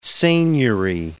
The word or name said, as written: seignory